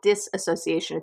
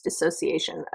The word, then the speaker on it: dissociation: Sorry, dissociation. It's not disassociation. It's dissociation.